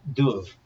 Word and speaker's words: Dub: part of the MacAlpin dynasty called Duv, D-U-B, which may have originally been pronounced like a V, and there the sons of Duv or Duf were the MacDuffs.